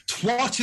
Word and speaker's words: twat: Twat is not a swear word. Twat is a rejected Teletubby. It is twat. It rhymes twat. With hat. It rhymes with cat.